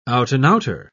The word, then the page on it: out-and-outer: British English